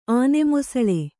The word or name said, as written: ಆನೆಮೊಸಳೆ